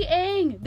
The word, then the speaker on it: Aang: It's literally Aang.